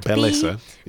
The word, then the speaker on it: Bellesa: Belessa. – B-E-L-L-E-S-A.